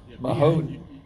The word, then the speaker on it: Mahoning: Am I pronouncing that right? – Yeah. – Mahoning. – Yeah, PA. Perfect.